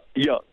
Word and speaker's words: Young: Tim and Paul, his son Paul, and then their chase partner Carl Jung, or Young, was it pronounced Young or Jung? – Young.